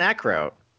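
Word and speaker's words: Eckroat: He was talking about that E-Crotch guy. I said, I know I just butchered that name. What was it? Hold on. What Ekrot. Austin. – Oh, Austin Ekrot. – Ekrot, yeah, Ekrot.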